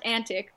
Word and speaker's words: QNTIC: And I, oh, and it's pronounced Antic, but it's spelled with a Q because it's cool.